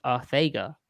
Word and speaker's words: Arcega: J-Jaw has stated his name is pronounced Arthega.